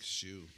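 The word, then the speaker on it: Hsu: Yeah, that was Mike Shue. Yeah, he's Asian, so that was spelled like H-S-U, I think.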